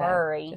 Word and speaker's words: buried: and it says B-E-R-R-I-E-D. She actually has to misspell this word to pronounce it correctly. – Because it still looks like Burry. It's Bury. It still looks like Bury. B-U-R-Y. Burr. – Just no, Bury.